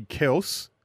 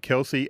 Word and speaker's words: Kelce: Their name is actually Kels, but everyone pronounced it Kelsey, and he just got sick of correcting people, so that's what it became.